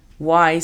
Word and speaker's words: why: Why significa por qué. La pronunciación fonética es U, A, I. Why.